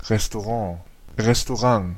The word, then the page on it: restaurant: Belgique (Bruxelles); [ʀɛs.to.ʁɑ̃]